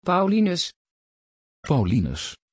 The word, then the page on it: paulinus: Dutch